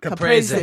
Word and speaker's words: Caprese: Caprese. – Caprese, yes.